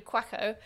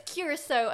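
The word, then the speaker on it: Curaçao: So it's better, as normally I pronounce it, it's blue quacko. – Quacko. – But it's curacao, I'm pretty sure, is okay. How you're meant to pronounce it.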